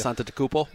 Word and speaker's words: Antetokounmpo: Giannis Antetokounmpo. – Antetokounmpo.